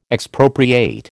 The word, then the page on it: Expropriate: American English